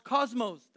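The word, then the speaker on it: cosmos (κόσμος): It's pronounced cosmos.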